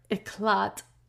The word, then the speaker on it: éclat: So it was a great object to her to escape all inquiry or éclat, which I put a question mark by the word.